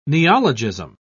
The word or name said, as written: neologism